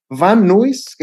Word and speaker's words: Van Nuys: Primero en el estudio que casero de un amigo y más tarde en su propio estudio en eh, cerca de Van Nuy. Fíjate que nunca sabía. Van Nui Van en California. – Nuy se escribe Nuys, N-U-Y-S. Van Nuys, es California, pero.